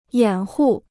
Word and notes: 掩护: yǎn hù

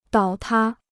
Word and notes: 倒塌: dǎo tā

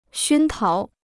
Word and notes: 熏陶: xūn táo

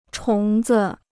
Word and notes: 虫子: chóng zi

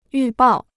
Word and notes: 预报: yù bào

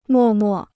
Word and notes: 默默: mò mò

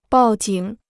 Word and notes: 报警: bào jǐng

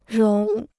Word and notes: 融: róng

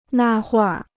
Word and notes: 那会儿: nà huìr

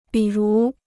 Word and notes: 比如: bǐ rú

